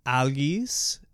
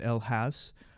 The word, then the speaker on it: Algis: Algis, sometimes pronounced elhas,